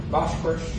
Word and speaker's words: Bosphorus: But to march there, they had to cross this, how do you pronounce that, darling, the Bosphorus? Bosphorus.